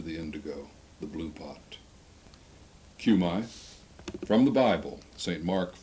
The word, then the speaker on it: Cumi: Would that I had known Aunt Cumi Woody, C-U-M-I pronounced Cumi.